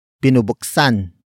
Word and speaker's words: binubuksan: Word for word it's binubuksan ko ang baul.